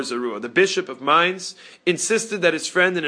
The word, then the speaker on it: Mainz: You're correct in English, but in German it's Mainz. – Mainz.